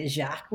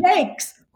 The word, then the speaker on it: Jacques: He's written so many books that we loved, but like Jacques, Jakes, it's Jakes.